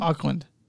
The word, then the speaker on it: Auckland: And this place is a place that Chris liked, and I can see why he liked it, because it's in Auckland. Is that how I pronounce it? Auckland? – Auckland. Auckland. – Auckland. Auckland. Capital.